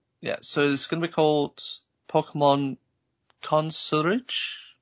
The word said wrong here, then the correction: Concierge: I've probably butchered that word. I will paste that in the chat and someone can tell me. Excuse me? What it's actually called. Concierge. Concierge, yeah.